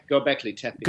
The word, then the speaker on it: Göbekli Tepe: Did I say that right? Göbekli Tepe? – Göbekli Tepe.